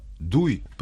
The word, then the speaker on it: 对: Слово «дуй» произносится в четвертом тоне, переводится как «направлять», «нацеливать», «воздействовать на», «адресовать».